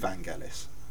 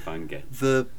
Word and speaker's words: Vangelis: I'm going to call him Vangelis. I know on the last show there was some debate about whether it's Vangelis, but... – He's always Vangelis.